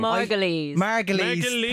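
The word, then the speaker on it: Margolyes: Margoyles? I'm not sure how to pronounce it. – Margoyles, I think. – Margoyles. – Thank you.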